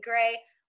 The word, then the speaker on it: Gray: Please follow me on Twitter at It's Jamie Gray, or better yet, on Instagram at Jamie Gray. And then my name is spelled J-A-I-M-I. It's different. G-R-A-Y.